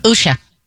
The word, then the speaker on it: Usha: Vance and how do you say his wife's names? Usha? – Usha.